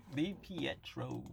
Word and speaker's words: DiPietro: Now we're going to go on to, now forgive me if I say his name wrong, Rick DiPietro? – Yeah, Rick DiPietro. – DiPietro.